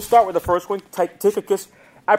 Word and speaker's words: Tychicus: I think the CH is silent, and it's pronounced as a K, rather. Tychicus.